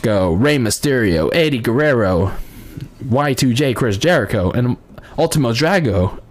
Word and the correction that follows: Ultimo Dragon: uh, Ultimo Dragon. Sorry, I mispronounced that.